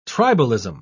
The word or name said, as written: tribalism